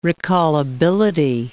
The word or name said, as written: recallability